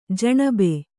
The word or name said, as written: ಜನಾಬ್